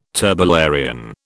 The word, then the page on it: turbellarian: British English